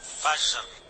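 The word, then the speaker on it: Fajr: Se dice Fajar. – Fajar. – Fajar. – Fajar. – Fajar. – Es imposible pronunciar. – La J es una G.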